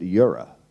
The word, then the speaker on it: Eora: Well, it's spelled Eora, but I was told it was, you say it, Eora.